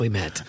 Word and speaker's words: Ouimet: Francis. We met. Uh, Uh, it's uh, he's uh, it's O U I M E T pronounced oh, okay.